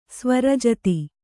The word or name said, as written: ಸ್ವರಜತಿ